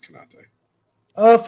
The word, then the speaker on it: Konate: You're right. It's Konate.